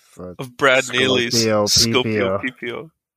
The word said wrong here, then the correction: Sclopio Peepio: Is there no L? No, there's. It's sc- Sclopio, S C L O P I O.